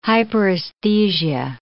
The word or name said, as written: hyperesthesia